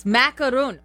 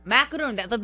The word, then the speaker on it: macaron: Macaron. Macaron. The French pastry. The French pastry. – Why is it all of a sudden three syllables?